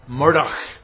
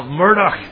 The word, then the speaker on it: Murdoch: One Lollard, Murdoch Nesbitt, Murdoch. Do you hear that? Murdoch. I can say it right as well. Murdoch.